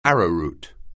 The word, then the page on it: arrowroot: anglais